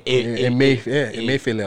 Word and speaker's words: Emefiele: Godwin Emefiele, I was right.